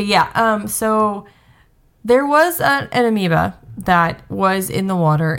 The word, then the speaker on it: amoeba: So the eighth one here is that the old water park River Country was actually closed due to a deadly amoeba. – Amoeba, sorry. It's spelled way different than it sounds.